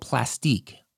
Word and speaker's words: Plastiq: It's called Plastique. I think that's the way you pronounce it. PLASTIQ.